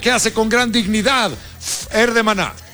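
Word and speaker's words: Fher: Fer con H, ¿no? – Fer. Así se pronuncia, Fer.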